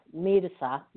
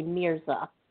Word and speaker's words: Mirza: In Spanish, it's pronounced Mirza, but normally people will say Mirza in English.